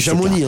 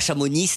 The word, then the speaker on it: Chamonix: Donc, Montels, non, parce que moi, je viens de, si, voilà, je raconte un peu ma vie, mais je viens de Savoie et on prononce pas les X, les S. – On dit Chamonix, on s'appelle. – Exactement, on dit pas Chamonix. On dit pas Chamonix, ni Chamonix.